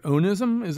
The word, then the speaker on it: onism: Well, I want to bring up another one of your words, which I believe is pronounced onism. Am I pronouncing it correctly? O-N-I-S-M? – Yeah, that's right.